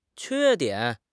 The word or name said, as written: que1 dian3